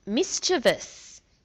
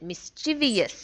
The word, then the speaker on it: mischievous: But there's also mischievous because people often mispronounce that mischievous.